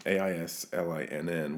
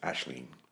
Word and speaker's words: Aisling: And she hers is an Irish name, Aislinn. We can call her Aislinn, though the proper Irish pronunciation is Aisling.